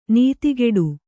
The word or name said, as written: ನುತಿಗೊಡು